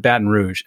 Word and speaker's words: Baton Rouge: And speaking of Baton Rouge, first of all, am I saying that right? – You are saying it right. Nope, you're good.